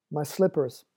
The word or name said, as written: my slippers